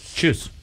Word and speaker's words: Tschüss: It's T-S-C-H-U-S-S or something like that. Tschüss. – Correct.